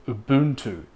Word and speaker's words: Ubuntu: and it's called Ubuntu. So to spell it would be U-B-U-N-T-U, Ubuntu.